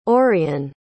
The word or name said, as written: auryon